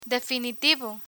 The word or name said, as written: definitivo